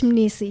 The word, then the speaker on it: Mnisi: Rich Mnisi. Is that how it's pronounced? – Rich Mnisi, yes.